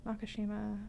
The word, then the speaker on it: Nakashima: Pronounced it correctly. Nakashima.